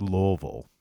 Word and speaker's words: Louisville: And they're like, you're not saying it right. It's Louisville.